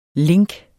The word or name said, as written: link